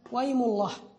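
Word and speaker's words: وايم الله: قال وحدثنا النفيلي قال حدثنا زهير بن معاوية قال حدثنا وأيم الله، هذه يمين وعندي أنا وكاتبها بهمزة القطع، والصواب أنها بهمزة الوصل، وأيم الله هكذا تنطق يعني.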